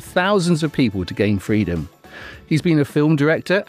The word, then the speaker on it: FRDM: Today, he is the CEO and founder of a company called FRDM, pronounced Freedom,